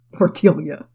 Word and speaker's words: tortilla: Like a 6-pound tortilla, kind of. – Tortilla. – How do you pronounce it? – Tortilla. – Oh. Well, I'll fix that then. Like a 6-pound tortilla, kind of. Is that better? – No, way better.